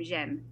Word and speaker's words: Jamie: Yeah, so Jamie in English, and her, Dominique pronounced her name Jem. – Jem, right.